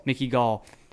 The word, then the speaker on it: Mickey Gall: He fought Mickey Gall, whatever, how you pronounce his name. – Mickey Gall.